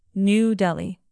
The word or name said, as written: new delhi